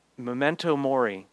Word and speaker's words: memento mori: memento morti, is that it? Or mori. Memento mori